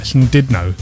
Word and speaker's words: Llandudno: But, yeah, I think I pronounce it right. Llandudno, that's how they pronounce it.